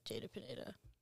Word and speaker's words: Jada Pineda: So it's like just seeing it written is prettier than just like Jada Pineda. Like it looks so bland on paper. – Well, as you said that, it also kind of, it rhymes, Jada Pineda.